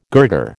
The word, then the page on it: girder: American English